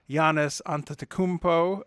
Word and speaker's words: Giannis Antetokounmpo: You have an extremely athletic player like Giannis Antetokounmpo of the Milwaukee Bucks. – You pronounced his name right.